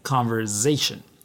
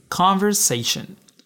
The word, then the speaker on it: conversation: Some learners mispronounce it with a Z sound, saying conversation, but the correct sound is conversation.